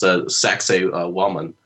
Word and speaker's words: Sacsayhuaman: And I believe it's pronounced Sacsayhuaman, which of course sounds, like something else.